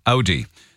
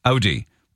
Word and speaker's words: Audi: The worst pronounced car in the world, and I own one, is Audi. The Irish say Audi, but it's actually Audi.